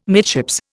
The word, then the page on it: midships: Indian